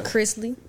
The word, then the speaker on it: Chrisley: That girl, Todd Chesley. – Say who? – Todd Chrisley. – Todd Chrisley. I said that nigga so crazy. – You said it wrong.